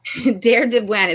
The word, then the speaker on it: Deir Dibwan: Der de Buen. – Der de Buen? – Der de Buen. It's spelled D-E-I-R-D-I-B-W-A-N.